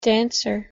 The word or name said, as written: dancer